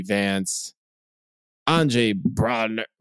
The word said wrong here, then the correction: Braugher: Wait, how do you say his last name? – Andre Brauer.